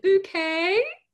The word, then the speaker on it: Bucket: What is that old show where, you know, Mrs. Bucket, but they pronounced it bouquet? – That's me.